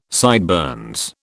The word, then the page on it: sideburns: British English